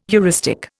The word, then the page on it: heuristic: Indian